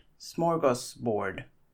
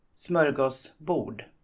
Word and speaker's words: smörgåsbord: I guess most of you are familiar with the word smorgasbord. But did you know that it's actually a Swedish word? And in Swedish, it's pronounced smörgåsbord.